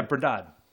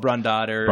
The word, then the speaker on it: brandade: Oh, yeah, brandade. Yeah, is that how you say it? – It's called brandade.